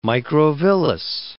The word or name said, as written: microvillous